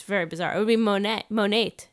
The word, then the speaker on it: Monet: In Spain, his name is Monet. Oh. I'm not sure if that's how you pronounce Monet in Spanish. Is that correct? – How do you spell it? – M-O-N-E-T, just like the painter.